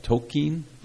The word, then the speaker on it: Tolkien: That's how you pronounce his last name? – Yes. Well, Tolkien. Yeah, I think it's only two syllables. – Okay. – In British. – I didn't know that. Tolkien? – Yeah.